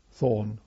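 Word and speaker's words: Toruń: First of all, to Thorn, it's spelt in English, I think Tolln is the nearer pronunciation in Poland, to a very large camp, which is an army camp called Stalag 357.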